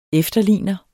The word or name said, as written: efterligner